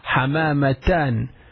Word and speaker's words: حمامتان: you will need to pronounce it saying حمامة you will say حمامتان you will add you will pronounce the ta and you will add ألف النون so it will be حمامتان did you understand it